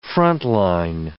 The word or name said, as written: frontline